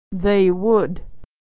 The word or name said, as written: they would